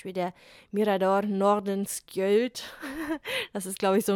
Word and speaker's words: Nordenskjöld: Und ich war so, ja, das wird wahrscheinlich wie ein Ö ausgesprochen. Nordenskjöld.